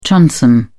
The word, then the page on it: transom: British English